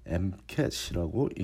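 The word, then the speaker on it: MCAT: 지난주에 이어 MCAT에 대해 좀 더 자세히 알아보기로 하는데 그 중에서도 가장 기본적인 사항부터 다시 짚고 가자면 MCAT라고 띄어서 읽지 않고 붙여서 MCAT이라고 읽는 것부터 확실하게 알고 자녀와 대화하면 좋을 듯 싶다는 점입니다. SAT처럼 MCAT라고 읽지 않습니다.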